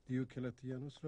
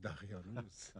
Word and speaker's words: دیوکلتیانوس: هنوز نمیتواند درست تلفظ کند. دیوکلتیانوس را میگوید دقیانوس.